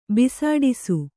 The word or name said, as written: ಬಿಸುಡಿಸು